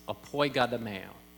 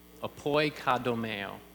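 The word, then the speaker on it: apoigadomeo (ἐποικοδομέω): It's hard to decipher the lettering, but I'm going to have you pronounce this. It is apoigadomeo, apoigadomeo, okay?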